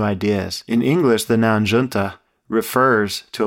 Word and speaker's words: Junta: Junta was another point in Fish's maturation, establishing their sound going forward. In Spanish, Junta, pronounced junta, means meeting, a convergence of sorts.